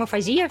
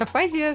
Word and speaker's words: афазия: Вот мы тоже говорим «афазия» всегда, хотя к нам все приходят и говорят «афазия», студенты, да, но мы говорим так же, как медики, насколько я понимаю.